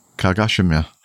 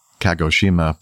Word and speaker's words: Kagoshima: Japan commuter AT-42 near Kagoshima or you could say Kagoshima, but I think that would be a very non-Asian way to pronounce that, Kagoshima, on October 12th, 2019, upset causes injury to flight attendant.